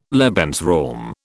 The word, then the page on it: Lebensraum: British English